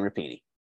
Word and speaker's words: Rapinoe: Yes, Megan Rapinoe.